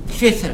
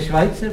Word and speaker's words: Schweitzer: No to se vyslovuje Švajce. – Co? – To je Švajcer, právě.